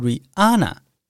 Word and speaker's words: Rihanna: 蕾哈娜的英文不是念作 Rihanna 哦，那个 H 其实不发音，应该要念作 Rihanna。